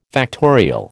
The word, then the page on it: factorial: American English